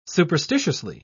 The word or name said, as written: superstitiously